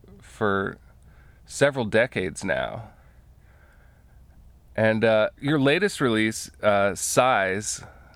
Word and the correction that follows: SCIS: is that pronounced correctly? S-C-I-S? – Oh, maybe Sizz. – Sizz? – You know? – Uh-huh. – Yeah, like sister album.